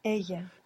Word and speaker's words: ella: Ella. – Now this is spelled ELLA. Double L in Spanish is pronounced Y.